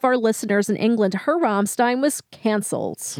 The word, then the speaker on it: Rammstein: You know, you stress to me the proper German pronunciation. – Rammstein. – Stein. – Rammstein. – Yeah, Jurgi gets on me if I say W's instead of V's.